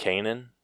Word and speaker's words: Kanan: Uh, Kanan. – Kanan. I am looking at it. I've never seen it spelled out. It's K A N A N, and I was like, Conan, uh, Kanan.